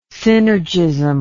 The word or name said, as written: synergism